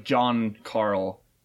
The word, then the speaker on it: Giancarlo: So then we also have Moff Gideon being played by, I'd assume you pronounce it this way, Giancarlo Esposito. Oh, that's like John Carl.